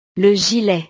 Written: le gilet

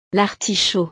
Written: l'artichaut

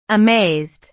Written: amazed

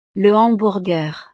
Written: le hamburger

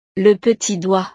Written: le petit doigt

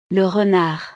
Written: le renard

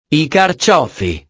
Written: i carciofi

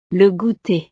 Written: le gouter

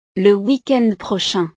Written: weekend prochain

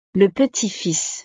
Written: le petit fils

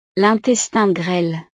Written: l'intestin grêle

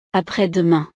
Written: après-demain

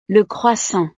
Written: le croissant

